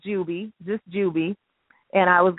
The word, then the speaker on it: Jubii: It's Jubilee, but it is spelled the way I wanted it, J-U-B-I-I, which is Juby.